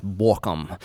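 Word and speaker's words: Bauckham: It's B-A-U-C-C-K-H-A-M. – Yeah. – But I think it's pronounced Borkum.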